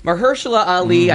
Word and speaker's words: Mahershala Ali: So male actor in a supporting role, just because I've learned to say his name correctly, Mahershala Ali. I no longer add that fifth syllable.